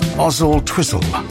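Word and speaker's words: Oswaldtwistle: Gordonstone says, it's pronounced Ozzle Twizzle without the W.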